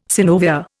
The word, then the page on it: synovia: Indian